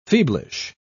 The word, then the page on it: feeblish: British English